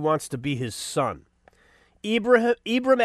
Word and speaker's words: Ibram: You're not familiar with Ibrahim. I'm sorry, Ibram. I don't want to mispronounce his fake name.